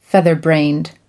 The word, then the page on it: featherbrained: American English